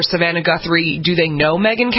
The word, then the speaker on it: Megyn: Born Megyn Marie Kelly.